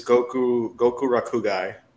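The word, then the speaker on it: Gokurakugai: The manga, it was Goku Rakugai. – I was close. I said Goku Ragi. I missed one syllable. – Yeah. – How do you spell it? – Goku. R-A-K. – Yeah. – U-G-A-I.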